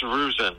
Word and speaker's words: Ruzzin: It's Ruzzin. – Ruzzin. – R-U-Z-Z-I-N.